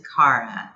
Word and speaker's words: Cara: In terms of pronouncing your name, is it Cara or Cara? – It's Cara.